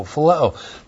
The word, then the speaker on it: phileo: Looks like that in the Greek, P-H-I-L-E, long O, phileo.